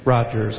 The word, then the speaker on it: Rodgers: This is Ken Rogers, the president of American Council of the Blind of Minnesota, and I will act as delegate. R-O-D-G-E-R-S is the last name. – Please spell it slower. – Ken Rogers, R-O-D-G-E-R-S. – Thank you. – Assistant delegate, alternate delegate will be Stephanie Hall, H-A-L-L, and nominating committee will be Ken Rogers.